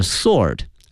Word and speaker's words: sword: 同时啊，同学们注意一下这个剑哈，它英文的发音啊，它看这个拼写里面有一个 W，但是呢它的发音呢是 Sword，是 Sword，所以同学们要注意一下。